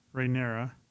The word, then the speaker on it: Rhaenyra: Rhaenyra.